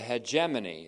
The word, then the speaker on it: hegemony: Consequently, in the Spirit, there is the hegemony, or the hegemony is how you'd pronounce it, H-E-G-E-M-O-N-Y, or the government of grace.